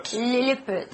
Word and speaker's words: Lilliput: Lilliput.